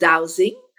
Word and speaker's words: dowsing: So explain to us what dose means and how can we dose our home to attract more abundance, success, healing, and love? I know that's one of your... – We pronounce it dowsing.